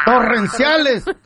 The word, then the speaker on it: torrenciales: ¿Cómo se dice? Terrociales. – ¿Terrenales? – No. Torrenciales.